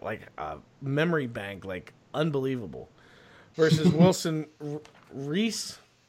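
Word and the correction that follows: Reis: Hayes? Yeah, Hayes. It's Portuguese.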